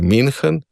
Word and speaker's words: München: medtem ko ü se izgovori kot i, torej München, Münchenski sporazum.